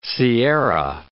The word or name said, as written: sierra